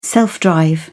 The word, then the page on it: self-drive: British English